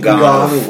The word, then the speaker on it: Ngannou: Ngannou.